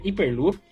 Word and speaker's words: Hyperloop: Tá certa a pronúncia, professor? – Hyperloop.